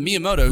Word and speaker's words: Miyamoto: But Miyamoto, who's the creator, I believe, of Mario. I believe I'm saying that right. – You're right.